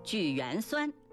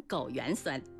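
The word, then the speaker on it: 枸橼酸: 举缘酸差点被我说成枸缘酸，朋友您是怎么读的呢？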